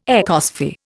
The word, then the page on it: ecosphere: Indian